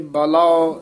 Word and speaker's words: बलाओ: हमै ममता जल बलाओ लोभ जलाओ अभिमान। – ए बलाओ जलाओ इस तरह उच्चारण करना है, बलो जलो नहीं कहना।